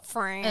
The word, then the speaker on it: Franz: I'm so Franz. – Just say it like that. – Yeah. – Franz. – That's what it is.